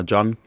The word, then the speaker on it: Gyan: Ja, in Ghana fangen einige Namen mit GYA an und dann wird der Name immer Ja ausgesprochen. Gab ja auch einen sehr, sehr berühmten Stürmer in Ghana, Asamoah Gyan.